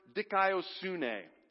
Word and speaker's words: dikaiosune: Righteousness is the Greek word dikaiosune. I've spelled it out here phonetically so we can all say it together. It's a little bit long. But let's say this word together. Ready? One, two, three. Dikaiosune. Let's say it one more time.